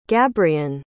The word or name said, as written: gabrian